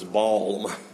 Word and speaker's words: balm: I didn't even know where Gilead was, but if they had bombs there, I didn't want to be there. And then I grew a little more and understood the pronunciation of the word was balm.